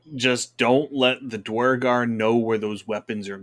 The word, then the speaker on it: Duergar: I can never say the name right. – Duergar, there we go.